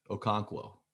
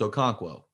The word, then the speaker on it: Okonkwo: Okonkwo. – Okonkwo. – Yeah. I mean, I look, feel like you just said it two different times, two, two different ways. – No, no, no. It's Okonkwo.